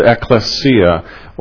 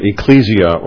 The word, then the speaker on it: ekklesia: And then there is the body of Christ, as we would call it, or the church, or the Greek word is the ekklesia, or some would pronounce it ecclesia or ecclesia.